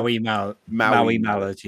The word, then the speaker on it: Maui Mallard: I always say it wrong. Mayo Mallard game as well. – Maui Mallard, yeah.